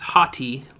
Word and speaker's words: Hathi: Also, I thought it was Hathi, like full of himself, arrogant Hathi, but it's apparently H-A-T-H-I. – Yeah. – Which I guess is pronounced the same, but spelled differently.